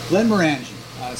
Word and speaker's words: Glenmorangie: Glenmorangie. – So next week we're gonna be sampling Glenmorangie, and see you next week.